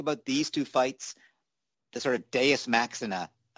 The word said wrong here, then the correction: deus ex machina: um, sorry, I mispronounced that, deus ex machina, uh, version of, of the way both fights ended? – It was great to see Jay come back.